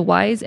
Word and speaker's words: Wiess: The Wiess Energy Hall? – Mm-hmm.